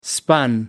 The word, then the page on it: spun: American English